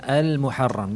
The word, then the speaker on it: المحرم: شهر الله الحرام You say with ألف ولام المحرم that's the proper way or you say شهر الله الحرام المحرم is the proper pronunciation of the word.